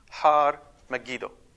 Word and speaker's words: Har Megiddo: Armageddon is a Hebrew word that in Hebrew would be pronounced Har Megiddo.